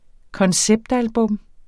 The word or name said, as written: konceptalbum